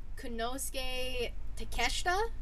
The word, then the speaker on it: Konosuke Takeshita: Taking on, let's see if I pronounce his name right, Konosuke Takeshita? – Yeah, I'll give you the thumbs up on that.